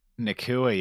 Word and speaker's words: Nacua: Puka Nacua, is that the? – Nacua, yeah, is Nacua. How they're pronouncing it, yeah.